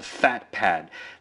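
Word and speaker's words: PhatPad: Today I want to talk to you about a very, very interesting note-taking application called FatPad. That's spelled P-H-A-T pad, P-A-D, that's all one word.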